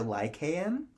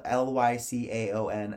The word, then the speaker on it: Lycaon: I would say Lycan. – Huh? – Lycan. – Well, it's spelled Lycaon, and I actually did look up the pronunciations. – I believe you. – And there's two accepted pronunciations. Lycaon okay. – Or Lycaon.